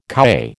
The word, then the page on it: kowhai: American English